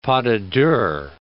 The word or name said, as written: pas de deux